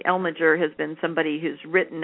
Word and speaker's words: Elmiger: Now, there's also in the listserv, and again, I couldn't find it because my computer was off, there's somebody named Elmiger, E-L-M-I-G-E-R, who speaks about tautodes. Are you familiar with him? – Say his name again. – E-L-M-I. G-E-R.